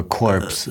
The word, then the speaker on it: Corps: Jetzt spricht man anscheinend so wie Chor, wie ein Gesangchor. – Mhm. Heißt aber Corps. – Aber ist die, C-O-R-P-S. Ja, ohne S, Chor. – Ah, okay. – Aber Französisch eben, Chor.